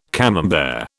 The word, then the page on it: Camembert: British English